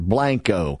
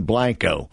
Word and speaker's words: Blanco: Kathleen Blanco or Blanco. I remember saying Blanco blanked out. You spell it B-L-A-N-C-O.